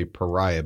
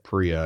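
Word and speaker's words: Paria: I want to say Pariah, but it's not Pariah. Locals call it Priya. I think they just skip over that first vowel in the name.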